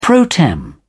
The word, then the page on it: pro tem: English; British English